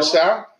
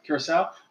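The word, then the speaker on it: Curaçao: Curacao? – Curacao. I never know how to say it. – Yeah. – I always mess that up. Curacao. – There's a little special character underneath there.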